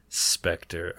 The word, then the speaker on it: Spectre: Number 12 on our list, we have Spectre. That's how we pronounce that, right? – Yes. – Spectre.